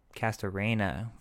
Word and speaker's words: Castorena: I spelled it C-A-S-T-R-O-N-A. – That's kind of close. – Kind of close? How do you say it, bro? – Castorena. – Castorena.